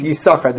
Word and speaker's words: Yisachar: Yeah, that's not an accurate pronunciation. It's Yisachar. This sin is silent. Rashi says so explicitly in Divrei Hayamim that the sin of Yisachar is a silent sin.